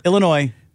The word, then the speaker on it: Illinois: I mean, the neighbor, Illinois, is not correct. Illinois. That's how you say it. – Putting the S on it literally makes me cringe.